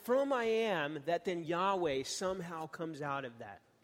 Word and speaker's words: Yahweh: It ends up being the what we scholars call the Tetragrammaton, which we believe is pronounced Yahweh.